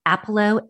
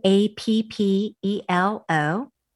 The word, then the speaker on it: Appelo: The name is spelled Lisa Appelo A P P E L O and two P's, one L.